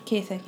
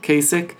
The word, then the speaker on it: Kasich: Moving on down, John, I can't even pronounce his name, Kasich? – Kasich, yeah. – Kasich, 6 delegates.